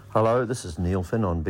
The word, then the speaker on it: Neil Finn: Rosetta told us at the top of the show that it rhymes with Schmielschmin. And of course, it is Neil Finn.